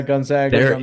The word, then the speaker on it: Gonzaga: Gonzaga U, right? – Yeah, Gonzaga.